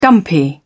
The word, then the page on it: dumpy: British English